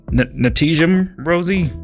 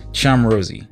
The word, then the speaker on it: Ntjam Rosie: If you want to look her up, her name is spelled NTJAM Rosie. Legit. Spent almost an hour today trying to figure out how to correctly pronounce that. Didn't want to get on air and be like, Natijam Rosie? No. Charm Rosie.